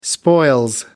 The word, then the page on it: spoils: British English